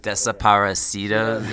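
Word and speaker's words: Desaparecidos: Or maybe it was just Despacito. Yeah. – Desaparecido. Yeah. Extra syllable in there.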